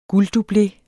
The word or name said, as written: gulddublé